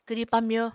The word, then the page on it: kwri pamyo: Apatani